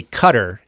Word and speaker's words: Qatar: It's mostly if you, I was listening to a pronunciation guide, and if you say Qatar, it's closer to the Arabic pronunciation than Qatar.